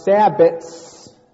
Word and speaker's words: sabbat: So first of all, those that observe Wicca have sabbats as opposed to sabbaths. It's pronounced sabbat with a T. There's no H.